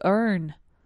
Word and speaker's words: earn: To earn. To earn, y se pronuncia como learn, pero sin la L.